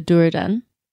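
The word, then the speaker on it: Dúnedain: He is the last, well, last, but he's one of the last of the Duradan. I think I'm pronouncing that correctly. – I believe you are.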